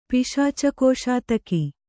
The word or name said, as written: ಪಿಶಾಚಕೋಶಾತಕಿ